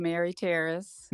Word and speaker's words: Mary Taris: Well, this early afternoon, I got Mary Taris, and I'd like to say good afternoon, and hopefully I pronounced your name correctly. – Yes, you did, Q-Bear. It's Mary Taris.